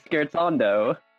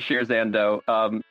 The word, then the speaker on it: scherzando: What do you think of when we say the word pronounced correctly, which is scherzando? – Music. – Ooh. Oh, that's correct. – So this was a me thing. All right, never mind. – No, everyone pronounces it Shrizando.